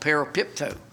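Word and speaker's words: parapipto (παραπίπτω): Parapipto. Not Pepto-Bismol, parapipto.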